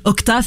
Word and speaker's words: Okja: donc sur Octa, ça s'écrit Okja, ça s'écrit ça se dit Octa, apparemment, ah en, langue originale.